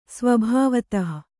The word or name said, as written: ಸ್ವಭಾವತಃ